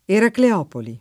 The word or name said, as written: Eracleopoli